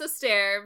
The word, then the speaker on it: Esther: So my name is pronounced Esther because it's the Spanglish pronunciation,